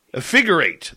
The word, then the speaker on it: Effigurate: Effurigate. Oh, I butchered that. Effigurate. There we go.